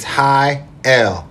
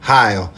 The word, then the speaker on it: Heyl: For my last name, I'm going to try to tell you guys that it's Heyl, Heyl. And it's one of the things that most people mispronounce, and I understand why wouldn't it? Heyl, you know what I'm saying? – Mr. Shane Heyl in the building.